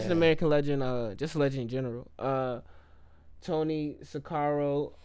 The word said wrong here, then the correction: Sirico: Sirico. – I always say his name wrong.